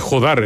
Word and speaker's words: Jódar: Siendo español, yo diría que es Jódar. También lo averiguaste por inteligencia artificial. – Le puso acento en la O, ya, para que bien.